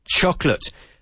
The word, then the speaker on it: chocolate: Chocolate. Emphasizing the first syllable and completely ignoring the second. We don't say choco. It's choc. Chocolate.